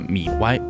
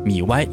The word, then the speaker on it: MIUI: 在这里呢，统一给大家科普一下，这个系统呢，在小米之前的发布会上，雷军呢是把它念作了米 Y，当然米 Y 也就是对小米系统的正解了。